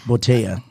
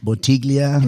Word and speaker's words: Bottega: So how do you pronounce it again? – Well, you know. – Bottega. – Bottega. – Not Bottiglia. – Now, I was corrected. – Right. – I was pronouncing it Bottiglia.